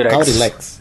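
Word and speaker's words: Calyrex: Então, esse lance da, da história e mais o, o novo lendário de Crown of Tundra, que é o Caulilex, é isso? dele, Como é que fala? Caulilex. É isso.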